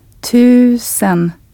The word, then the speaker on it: tusen: The word for thousand with a long Swedish U sound. – Tusen.